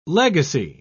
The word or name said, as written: legacy